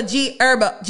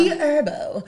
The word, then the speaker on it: Herbo: Okay, so the next item on the scroll, G Herb. I always say his name. – With the car? – Yeah. Okay, so G Herbo. – Herbo. – I always want to make him a herb.